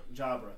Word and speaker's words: Jabra: Jabra.